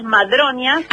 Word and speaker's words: Madronias: te escribe directamente un mensaje a @tallerlasmandronias, ¿es así? – Es Taller Las Madronias. – Las Madronias, sí, perdón, lo dije mal, Las Madronias.